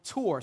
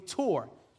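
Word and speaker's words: tor (תּוּר): And that word choose in the Old Testament is the word tor. Say it with me, tor. Say it like you mean it, tor.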